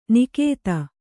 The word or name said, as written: ನಿಖಾತ